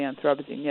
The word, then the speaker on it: Anthropocene: Anthropocene period? Did I pronounce that right? – Help us understand. There are different ways to pronounce it, but the Anthropocene, yes.